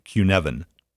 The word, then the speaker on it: Cynefin: And that is spelled C-Y-N-E-F-I-N. It's a Welsh word. It's pronounced Cunefin.